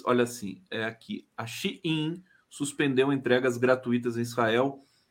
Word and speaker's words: Shein: O Jomiag, querido jornalista da TVT, está me chamando a atenção aqui, diz que é, a pronúncia da, da empresa de vendas chinesa não é Shine, é Shein. Shein.